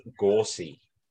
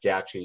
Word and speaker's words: Gauci: His name is actually pronounced Gorsi. So there you go, folks. No one has come out with that one yet, but his name is not actually pronounced Gauci. It's Gorsi.